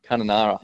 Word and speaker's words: Kununurra: Let me try and say it right. Kununurra? – Kununurra. – Yeah, that's it.